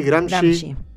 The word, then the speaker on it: Gramsci: ¿Cómo Gramsci, Gramsci. Muy bien. Gramsci le dicen, Gramsci se escribe.